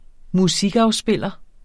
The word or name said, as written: musikafspiller